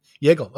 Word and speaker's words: Jagel: Jagel.